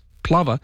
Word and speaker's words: plover: The bird is properly pronounced plover because Graham, who's Scottish, was the person that told us the proper pronunciation.